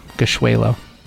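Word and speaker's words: Gashweylo: Gishwelo.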